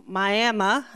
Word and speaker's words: Miami: And then by 11, Isla, Marge, and I were heading down to Miami, is how they say it, Oklahoma, to go to the House of David service that night for Shavuot, which is the Feast of Pentecost.